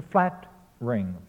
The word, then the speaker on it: flattering: However, in line 183, he ignores the need to syncopate the word flattering, F-L-A-T-T-E-R-I-N-G. He says flattering in 3 syllables. It should be reduced through syncopation to flat-ring, flat-tring.